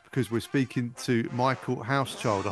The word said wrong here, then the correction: Hauschild: But it's like a house shield.